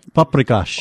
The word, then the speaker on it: paprikash: Paprikash.